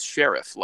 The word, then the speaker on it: Scherff: But the man himself says Scherff, like a sheriff.